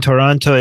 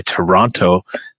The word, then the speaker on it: Toronto: By the way, Sang is telling you that the second T in Toronto is silent. Please correct or leave the city.